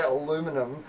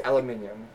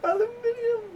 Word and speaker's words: aluminium: Like, I'm not going to suddenly say aluminum just because you guys have decided that you can't. It's aluminium. – Aluminium.